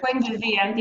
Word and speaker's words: Buen Vivir: I'd like to go now to just very quickly the area that you study, which is the movement called Buen Vivir. Is that, how's that pronunciation? No good, right? – Buen Vivir. Yeah, that's not bad.